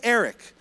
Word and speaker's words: Eric: Like Koreans can't even say Eric. So like half my genealogy can't pronounce my name. There is no R sound in Korean. There's no it's only so it's like Elik, Eliku.